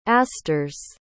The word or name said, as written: asters